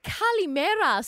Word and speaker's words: Kalimera: Kalimera, Sula.